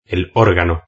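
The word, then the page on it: el organo: Spanish (Spain)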